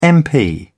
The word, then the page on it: mp: British English